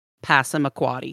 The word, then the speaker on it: Passamaquoddy: They were founded in 1604 on an island in Passamaquoddy Bay. Shout out old school Disney Peach Dragon for giving me any idea of how to pronounce Passamaquoddy, because that is a weird looking word.